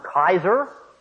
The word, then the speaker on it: Caesar: They adopt the term Kaiser for their ruler, which is in fact the close as I know, the proper pronunciation in Latin of Caesar from ancient times anyway.